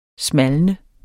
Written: SMALNE

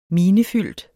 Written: minefyldt

